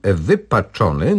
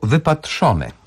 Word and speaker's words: wypaczony: Tym razem pan Mariusz prosi o zajęcie się słowem wypaczony, notorycznie wymawianym jako wypatrzone.